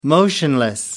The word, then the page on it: motionless: American English